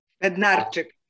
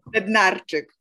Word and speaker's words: Bednarczyk: Bednarczyk. – Bednarczyk. So it's like a jig.